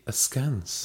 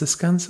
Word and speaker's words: askance: You say askance, I say askance. – It's not ah, it's askance.